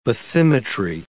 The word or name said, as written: bathymetry